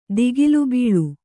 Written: ತಗುಲುಬೀಳು

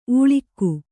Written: ಉಳ್ಕ

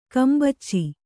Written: ಗುಂಬಚ್ಚು

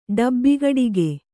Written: ಡಬ್ಬಿಗಡಿಗೆ

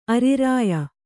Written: ಅರಿರಾಯ

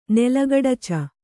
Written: ನೆಲಗಡಚ

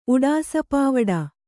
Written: ಉಡಾಸಪಾವಡ

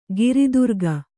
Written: ಗಿರಿದುರ್ಗ

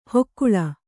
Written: ಹೊಕ್ಕುಳ

